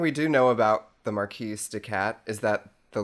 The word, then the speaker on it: Marquise: Am I pronouncing that right? Marquise? – Yes, that is the name, Marquise.